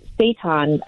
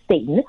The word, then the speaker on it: seitan: And so they've got experience making the seitan, which some people may mistakenly pronounce as Satan.